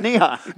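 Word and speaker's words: ni hao (你好): I thought ni hao was a great player. You got a shank. How do you say that? – It's ni hao. – Ni hao. Ni hao. Okay.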